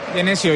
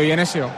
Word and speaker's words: Genesio: Ahí está Bruno Genesio o Genesio, ¿cómo lo pronunciamos? – Genesio, Genesio.